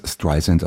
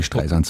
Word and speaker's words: Streisand: Aber in Amerika spricht man es Streisand aus. Aber Sie können gerne Streisand sagen.